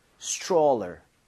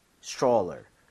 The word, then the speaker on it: stroller: 읽을 때 여기 TR mom 이렇게 되면 얘가 T하고 R을 충돌을 하면서 치읓 비슷해집니다. 그래서 스트롤러가 아니라 입 모양을 좀 모으면서 stroller 이렇게 해서 발음을 해보는 거예요.